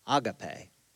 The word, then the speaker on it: agape: However, as I studied it and tried to understand the proper pronunciation of this word, in the Greek, it actually should be called agape, which doesn't really matter to me because I know it as agape, and that's what I'm going to call it this morning.